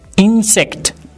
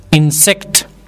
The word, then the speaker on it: insect: Insect. As you can see, I and N are bold. In bold. قسمی که میتونید ببینید حروف I و N درست نوشته شده. N. او بخش غلیستر تلفظ میشد. Insect.